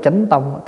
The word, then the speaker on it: chính tông: Rồi kế đến là phần chính tông. Chính tông tức là gì? Có khi đọc là chánh tông.